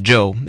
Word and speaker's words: Chou: Spell it C-H-O-U if you want to be exotic. It's still pronounced Joe, and that's his name.